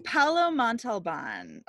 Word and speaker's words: Paolo Montalban: Paolo Montalban.